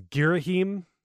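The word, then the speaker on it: Ghirahim: And this is Girahim. At least that's how I pronounce it. Brian? – Yeah, that's it.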